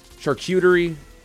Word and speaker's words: charcuterie: You pronounce it like this. Charcuterie. – Charcuterie. – Charcuterie. Very good. – Oh, mamma mia. – It's not cute. It's charcuterie. Yeah.